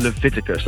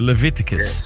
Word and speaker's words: Leviticus: Maar ik weet alleen niet hoe spreek je dat uit? Zeg je uit maar. – Je spreekt het uit als de Leviticus. Dat is een Bijbelse term. – Aha, de Leviticus.